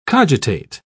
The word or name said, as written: cogitate